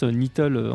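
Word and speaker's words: Knittle: Il y a vraiment des très très bons instrumentistes dedans, notamment Stéphanie Nittle, alors c'est KN mais on prononce Nittle, on prononce pas le K en anglais.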